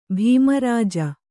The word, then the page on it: ಭೀಮರಾಜ: bhīma rāja